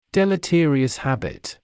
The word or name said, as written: deleterious habit